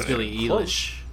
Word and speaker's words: Billie Eilish: Did I even pronounce it right? – Billie Eilish. – Billie Eilish? Eilish? Eilish? – I say English. – Oh, it's Billie Eilish.